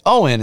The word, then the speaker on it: Eoin: And the reason I had to look it up is because Owen is spelled E-O-I-N.